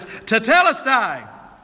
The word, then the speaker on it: tetelestai: I could have gone if you didn't know Greek and you'd have thought I was pronouncing it right, but it's tetelestai. Tetelestai, it is finished.